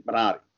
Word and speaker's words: Minari: Now it's a matter of getting everyone to say it the right way, you know, which is Minari.